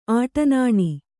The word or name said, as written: ಆಟನಾಣಿ